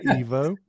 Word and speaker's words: Ivo: And of course, in English you pronounce an E like an I and an I like an E in German, or it's the other way around. But originally it is pronounced Evo.